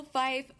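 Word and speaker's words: Fiffe: The next up is All New Ultimates number 3 by writer Michael Fife. – That one. – Fife. – Yeah, we've definitely mispronounced his name many times. Thank you.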